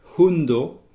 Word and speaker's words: hundo: Hundo, avec un H aspiré pour chien. H-U-N-D-O.